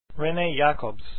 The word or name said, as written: Jacobs